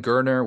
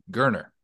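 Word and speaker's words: Geriner: Or, you know, if he fails, at least you got Holden Gurner, which his mom told the broadcast crew, it's like Turner with a G. Gurner. That's it. Gurner. Easy to remember.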